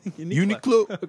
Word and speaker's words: Uniqlo: Is it pronounced Uniqlo? – Uniqlo.